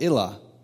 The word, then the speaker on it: Ilah: It says, Jesus, peace be upon him, spoke Aramaic and called God Ilah, which is pronounced the same as Allah.